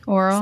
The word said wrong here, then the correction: aural: Say it right. – Oral. – Not, no. Aural. – Aural. – You're making it worse. – You're very aural. – That's better. Thank you.